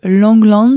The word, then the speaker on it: Langlands: La démonstration de la correspondance de Langlands, je ne sais pas si je prononce bien.